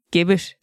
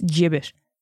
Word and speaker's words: gibbet: So if you're like me, you know, fucking idiot, and you're going around, and you're going around pronouncing gibbet as gibbet, well, stop it right now. It's gibbet. Okay.